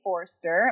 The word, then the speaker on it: Foerster: I'm on Instagram and Facebook, Vanessa Faye Forster. My last name is spelled a little bit weird, F-O-E-R-S-T-E-R.